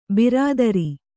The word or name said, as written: ಬಿರಾದರ